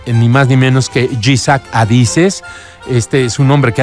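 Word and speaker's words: Ichak Adizes: Te voy a invitar a que busques su video de PAEI y se escribe con Y, así como si fuera Isaac, pero con Z, Y-Z-A-K, Jisak Adises, así.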